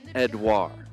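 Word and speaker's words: Édouard: Your Palace striker, who's going to make all of us rethink our forward strategy this week, is none other than Odson Edouard. Yeah, if you want to be real French Odson? About it, you don't really pronounce the final D in his last name.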